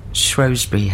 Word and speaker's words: Shrewsbury: Yeah, see, Mycenae House. Surely you meant Shrewsbury House. – Well, it's my first time here at Shrewsbury House. – Yeah, I never know how to say it. – Well, my folks are from Shropshire, and they would call it Shrewsbury House.